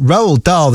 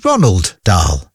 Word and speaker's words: Roald Dahl: And also for years that I thought Roald Dahl, the author, was called Ronald Dahl, like Ronald McDonald.